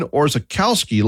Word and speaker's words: Orzechowski: Ben was born today in 1947 in Cleveland, Ohio, and he had a long Polish last name that some folks pronounced his last name as Ben Orzechowski, like it looks. Others pronounced it in more of a Polish fashion, changing the W in his long last name to a V, and Ben himself tried to make it easy.